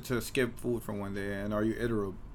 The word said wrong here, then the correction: irritable: Is that how you pronounce that word? – Irritable.